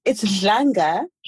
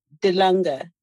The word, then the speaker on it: Dlanga: It's Dlanga. – Dlanga. I'm going to get it. Okay. – Yes. You can say Dlanga. You just so that, you know, people spell it right. D-L-A-N-G-A.